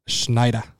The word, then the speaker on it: Sznajder: Schneider. – Yeah, exactly. It's like Schneider. S-Z-N-A-J-D-E-R.